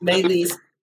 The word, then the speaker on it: Maelys: It's pronounced Maelys. It's spelled M-A-E-L-Y-S.